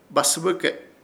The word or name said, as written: Basɨbɨke